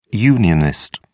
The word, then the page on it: unionist: British English